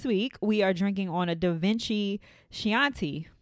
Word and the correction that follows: Chianti: Chianti. – There we go. I was like, as soon as it came out, I know I said it wrong. Chianti.